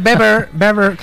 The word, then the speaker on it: Weber: Y está Manfred Weber, que se dice Weber, no se dice Weber, ¿vale? Sí, sí.